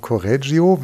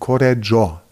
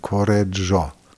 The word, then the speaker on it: Correggio: Der wird nicht ausgesprochen Correggio, wie wir das immer sagen als Deutsche, sondern wenn man Italiano richtig versuchen will, dann Correggio. Das heißt, das I müsste man so halb oder ganz verschlucken.